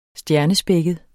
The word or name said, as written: stjernespækket